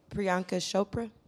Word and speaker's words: Priyanka Chopra: Priyanka Chopra.